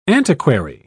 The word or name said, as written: antiquary